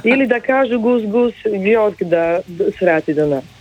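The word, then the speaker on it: GusGus: Gus Gus, ja to zapamtim, zato je to nastalo od Kus Kus.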